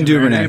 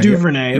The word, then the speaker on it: Duvernay: Have we said on the pronunciation? – Devin Duvernay. Yep, Devin Duvernay. – Duvernay. Yep. That makes a lot more sense.